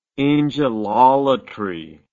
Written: angelolatry

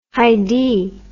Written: Haidee

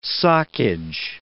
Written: soccage

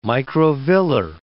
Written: microvillar

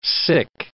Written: sick

